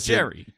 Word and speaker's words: Gerry: They're like, pick out an outfit that most, you know, can show Gary, like, your true self. – Is his name Gary? – Yes, and it's spelled G-E-R-R-Y. – Get out of here with that shit. – Jerry.